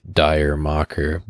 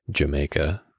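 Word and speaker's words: D'yer Mak'er: For those of you who are unaware, by the way, that's the Zeppelin song that the way that it's spelt, you would think it's pronounced Dire Mocker, but that's incorrect. It's in reference to some British joke about going to the islands. So it's you say it like Jamaica.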